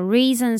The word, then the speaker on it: recent: 首先我们看到 recent study，这里的 recent 我们可以不用发它的 T 的音，念作 reason 就好了， recent study。